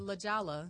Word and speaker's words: La Jolla: Otherwise pronounced La Jolla.